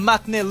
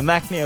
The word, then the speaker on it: maknae: Yeah, so I'm the maknae of Y101. – Yes, you are. – That's nice. I'm proud to have that title. I'm maknae here.